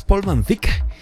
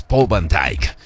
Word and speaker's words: Paul Van Dyk: Es alemán y en realidad la pronunciación correcta es Paul Van Dyk. Pero bueno, pues alguien, sobre todo en Estados Unidos, ¿no? Fue como agringarlo y es Paul Van Dyk.